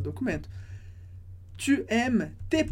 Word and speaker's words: aimes: Mas, atenção, s e e no final da palavra a gente não pronuncia. Por isso, tu aimes.